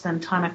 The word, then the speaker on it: Ventana: You're the first person that doesn't know Spanish that could pronounce Ventana correctly.